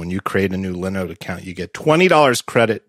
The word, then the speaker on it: Linode: I used to pronounce them Linode, but it's Linode, like Linux, because it's Linux hosting.